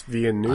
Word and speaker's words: Villeneuve: Denis Villeneuve.